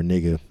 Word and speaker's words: NGGA: You okay, NGGA or nigga is the pronunciation, but it's that was like a performance art piece.